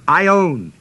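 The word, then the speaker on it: aion: This word everlasting is the Greek word Aionios, A-I-O-N-I-O-S, A-I-O-N-I-O-S. First O is a long O. And it comes off of the noun Ion, A-I, long O-N, which is translated age, in the plural ages.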